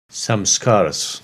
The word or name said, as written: Samskaras